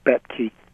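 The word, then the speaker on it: Boettke: Is that how I pronounce the name? – Yes, Betke.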